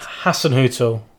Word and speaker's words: Hasenhüttl: Come on, say his name. – Ralph. – Yep, got that bit right. – Hasenhüttl. – Very good.